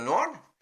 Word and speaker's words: Nord: Of course, we don't pronounce the D at the end of the Nord.